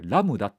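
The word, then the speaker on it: Lambda: ラムダ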